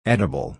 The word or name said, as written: edible